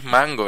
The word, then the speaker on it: mango: yo entiendo que la en verdad es mango, ¿verdad? En casi todos sitios, en Puerto Rico es que le pusimos el acento en la O.